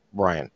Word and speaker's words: Bryan: And then I, just out of maybe vanity, I looked at the definition for Brian, my name, Brian. And I called my mom and asked my mom about it. I said, Mom, my name is spelled B-R-Y-A-N. Why isn't it B-R-I-A-N?